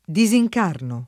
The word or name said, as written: disincarnare